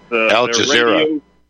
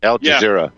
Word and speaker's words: Al Jazeera: Al Jazeera. Al Jazeera.